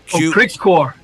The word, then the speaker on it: Qrixkuor: Is it Qrixkuor? – Qrixkuor. – I was close.